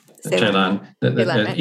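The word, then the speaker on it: Celan: Ceylan. Either Ceylan or Ceylan if you use a Romanian pronunciation or a German pronunciation.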